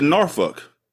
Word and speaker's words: Norfolk: And she's like, nah, it's a Norfolk. She said it right, Scar. – Yes, she did. – Which means she's probably from your part of the world. – Or she just knows that you don't pronounce the L.